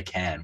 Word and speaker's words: Cairns: Was it pronounced Cairns? Did I do that wrong? – I think Can. – Can. – A lot of, like, I actually was in Cairns for a month, and they say Can, which, but no one else says it that way.